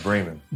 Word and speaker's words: Braman: Yeah, Braman. – Braman. – Long A, yeah.